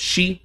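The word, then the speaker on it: shi (四): And it's a lot easier to figure out why there's the fear of the number four, because it's pronounced, in Japanese, pronounced shi, which is the same way that the word death is pronounced.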